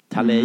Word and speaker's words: Talay: Och till slut eh, tog hon sig i mål på 13,0, eh, en femteplats, bara tiondelen bakom regerande VM-trean Alina Tallay. Tallej. Mm uttalas.